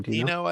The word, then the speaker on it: Giacchino: Giacchino, I think. Giacchino, probably. Giacchino? I think in Italian, you pronounce that G-I-A is like Giacomo instead of Giacomo.